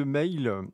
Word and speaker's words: Mhaol: En tout cas, c'est les Irlandais de Mail. Alors, ça s'écrit M-H-A-O-L avec le H entre parenthèses.